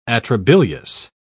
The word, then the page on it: atrabilious: British English